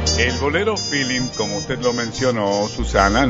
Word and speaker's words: feeling: que aunque es una palabra inglesa que se escribe totalmente diferente y se dice feeling,